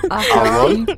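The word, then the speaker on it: Ahearn: Le réalisateur c'est Joe Aera. mais comment on prononce ça Ahern.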